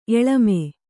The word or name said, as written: ಎಲಮ